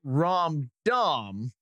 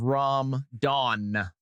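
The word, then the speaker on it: ram-don: In the commercial break, I ended up pronouncing it Rom Dom instead of Rom Don with an N.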